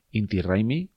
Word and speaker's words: Inti Raymi: Es este Inti Raymi, que es como se pronuncia en quechua.